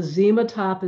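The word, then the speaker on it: Xematop: It's spelt with an X, not a Z, said with a Z, but Zematop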